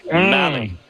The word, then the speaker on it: Malley: Yeah, rhymes with rally, not Molly, Tyler Malley.